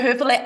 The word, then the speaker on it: Hoeferle: So you can find me on all social media, Brigitta Hoeferle, Brigitta, last name Hoeferle. You spell it just like you pronounce it, Hoeferle, Brigitta Hoeferle.